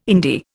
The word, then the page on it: indie: Indian